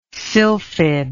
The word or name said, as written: sylphid